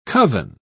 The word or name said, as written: coven